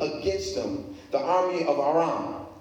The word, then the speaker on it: Aram: So in Second Kings chapter 6, a war is going on um, an ancient Aram, that's how the name is pronounced, was at war with Israel.